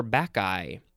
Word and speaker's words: Bacchae: Okay, we are going to move on to bacchi, or bacchi. That's how it's pronounced. It is spelled capital B-A-C-C-H-A-E. Both of those pronunciations were good. It is a noun from 1639. 1. The female attendants or priestesses of Bacchus, B-A-C-C-H-U-S.